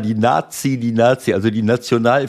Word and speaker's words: Nati: Nazi. In Deutschland recht schwierig, wenn man es so feiert, aber so wird es halt ausgesprochen. – Genau so, also die Nationalelf, die Nati geschrieben, ich habe irgendwas haben die da gesungen.